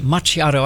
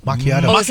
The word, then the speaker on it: macchiato: What is it? Macchiato? I don't know. – Macchiato. – Oh, I didn't even say it right.